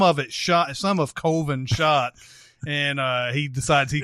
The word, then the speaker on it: Coven: And they're trying to make a horror movie called Coven, which is pronounced Colvin.